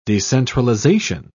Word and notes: decentralisation: British English

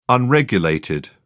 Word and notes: unregulated: British English

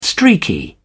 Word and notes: streaky: British English

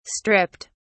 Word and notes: stripped: British English

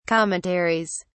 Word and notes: commentaries: British English

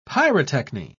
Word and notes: pyrotechny: British English

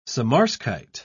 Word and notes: samarskite: British English

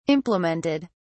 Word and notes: implemented: British English

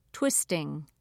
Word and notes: twisting: British English

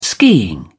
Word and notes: skiing: British English

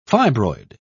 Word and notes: fibroid: British English

